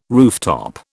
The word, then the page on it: rooftop: British English